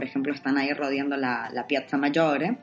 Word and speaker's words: Piazza Maggiore: Entonces, nada, yo recomiendo empezar por la Piazza Maggiore, ¿no? La quiero pronunciar así con ese acento italiano. – Te ha quedado muy bien.